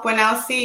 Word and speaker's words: Bwanausi: Bwanausi, you did well.